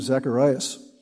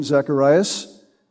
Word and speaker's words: Zacharias: Zacharias. Both of those names are legitimate spellings or pronunciations of the name.